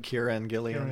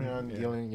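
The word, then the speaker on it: Kieron Gillen: It's written by Kieran Gillian. I'm saying that right? – I think so. – All right. – Kieran Gillian. – Kieran Gillian, yeah.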